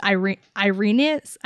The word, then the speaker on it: Irenez: And we later find out that this woman's name is Irenez. I don't know how to say her name. – Her name is Irene with a silent Z.